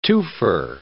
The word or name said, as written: twofer